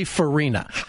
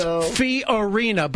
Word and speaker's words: Fiorina: And have we gotten Macbeth to stop saying Carly Farina? Because Farina was a character in The Little Rascals, okay? – Yes. – It's Fiorina, but then again, no one's pronouncing her name anyway.